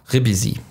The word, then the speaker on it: Ribisi: Ribisi, so wird er ausgesprochen.